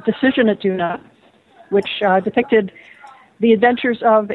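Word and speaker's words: Doona: Talk to us a little bit about this book, Crisis on, pronounce the name for me. – Duna. – Duna. There's no way there, but I'll go with that.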